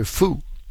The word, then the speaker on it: Fu: Your last name is spelled simply just the letters F-U, Dr. Fu.